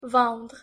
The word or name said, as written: vendre